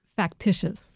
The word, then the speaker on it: Factitious: Factitious. – Factitious, okay.